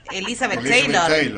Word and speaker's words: Elizabeth Taylor: Elizabeth Taylor.